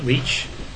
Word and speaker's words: Leach: Brian Robert Leach. Brian, B-R-I-A-N, Robert, R-O-B-E-R-T, Leach, L-E-A-C-H, and my title is the Chief Risk Officer of Citigroup.